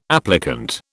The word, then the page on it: applicant: British English